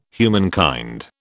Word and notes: humankind: British English

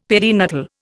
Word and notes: perinatal: Indian

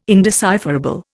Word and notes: indecipherable: Indian

